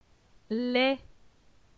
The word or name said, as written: Les